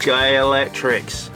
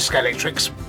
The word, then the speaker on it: Scalextric: Scalextrics, yeah. – Everyone calls it Scalextrics, but it's Scalextric. Oh. It's really weird to pronounce because it doesn't feel right, does it? – No.